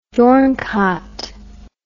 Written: dornkaat

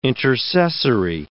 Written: intercessory

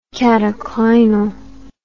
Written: cataclynal

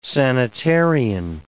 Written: sanitarian

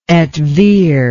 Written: et vir

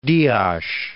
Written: Dias

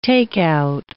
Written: take-out

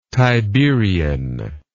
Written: tiberian

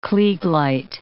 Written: klieg light

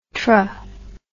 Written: tra

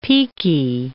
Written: piki

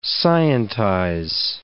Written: scientize

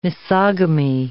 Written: misogamy

